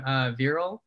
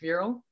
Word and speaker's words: Viral: I don't know exactly how my parents decided to pick Viral, but I know that the Indian way to spell it is V-I-R-A-L. So it could be mispronounced as viral, right?